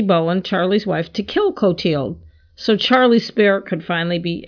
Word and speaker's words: Clothilde: C-L-O-T-H-I-L-D-E, Clotilde, is how I'm pronouncing it.